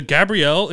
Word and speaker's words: Gabrielle: There's a Gabrielle in France. It's still pronounced Gabriel.